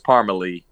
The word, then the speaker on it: Parmalee: Now, is it pronounced Parmalee or is it Parmel? – No, it's Parmalee.